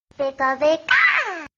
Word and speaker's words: Pika: You pronounce the name of the application wrong. So it's actually this. Pika Pika.